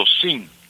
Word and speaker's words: sim: It's almost like an N, but you don't really sound, it just dies, you know. You says S-I and the M is almost, SIM. – How do you say that? – Fades away. – Fades away, exactly. Thank you. So, seem. – Seem. – Exactly. Good job. And the no would be no.